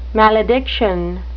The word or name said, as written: malediction